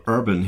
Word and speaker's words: Urban: Kannst du mal Fakten checken, wo die Roots von Carl Urban herkommen? – Also ich bin mir sehr sicher, dass er Urban ausgesprochen wird, weil ich das in Interviews und so auch schon gehört habe.